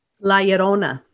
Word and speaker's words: La Llorona: Uh, how do you pronounce it again? – La Llorona. – Okay, there we go.